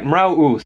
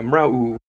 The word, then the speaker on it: Mrauk U: You said it's in Mrauk U is the name of the place? – Right, Mrauk U. So the K is silent on that? Yeah, Mrauk U.